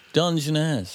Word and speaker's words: Dungeness: It's got a U in it and three syllables. Dungeness.